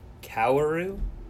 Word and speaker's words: Kaoru: Kaoru? – Yeah, they all look like they shouldn't be pronounced that way, but they are.